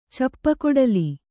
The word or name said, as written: ಚಪ್ಪಗೊಡಲಿ